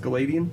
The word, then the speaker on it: Gileadian: It's Gileadian, surely. – You think it's Gileadian? It's Gilead, E-A-N.